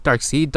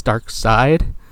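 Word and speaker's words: Darkseid: He's like, wait, Dark Seed, Dark Side, which I'm sure enough people have made that have not made that distinction that it's Dark Side.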